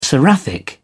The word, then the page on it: seraphic: British English